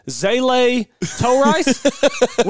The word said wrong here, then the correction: Zalatoris: Will Zalatoris.